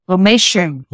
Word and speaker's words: Lomatium: I think it's pronounced Lomatium, I think. – Lomatium. – Lomatium. Okay.